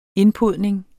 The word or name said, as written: indpodning